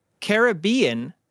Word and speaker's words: Caribbean: So it's Caribbean. Is that correct? Yes. Okay. So yes. – I'm going to say Caribbean. – You're actually right. – Okay. – Yeah. Caribbean is the native pronunciation that would have originated from the islands.